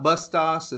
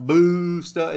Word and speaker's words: Bustos: It is spelt like this, B-U-S-T-O-S Training. So it's not Bustos. It's not Boosta. It's Bustos.